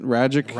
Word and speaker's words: Ragic: But yeah, Ragic, or Ragic is how you say it. Ragic rhymes with magic.